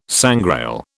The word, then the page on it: sangrail: British English